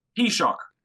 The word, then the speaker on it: Taoiseach: Taoiseach.